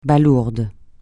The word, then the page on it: balourde: francais